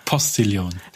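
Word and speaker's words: Postillon: Jan, der Postillion. Ich habe gemerkt, dass ich das immer auf Englisch ausspreche. Wie heißt es? – Postillion.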